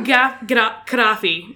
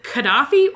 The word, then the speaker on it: Gaddafi: So, Muammar Gaddafi, and I will fully admit that the syllable of his first name is hard to pronounce. You can either pronounce it Gaddafi or Gaddafi.